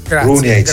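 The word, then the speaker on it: Runjaić: E questo lo lascio pronunciare a te, che sei esperto di lingue Runeic. Runeic, si legge così come lo dici.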